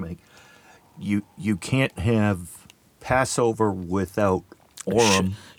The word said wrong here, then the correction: Shavuot: Shavuot. – Shavuot. See, not even close.